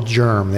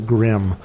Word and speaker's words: GIRM: Some people call it the germ. They like to say the G I R M. – Yes. They like to pronounce it German. And I remember one particular woman who mispronounced it all the time as the grim.